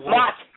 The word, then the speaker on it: Mott: You're talking about Mott. Mott. M-O-T-T Street. – M-O-T-T Street? – Mott.